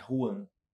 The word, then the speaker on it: Wuhan: Xuan. Xuan. Como, é, é como é que fala a cidade? É Wuhan. Não. Tá. Você escreve W-U-H-A-N.